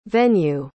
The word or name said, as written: venu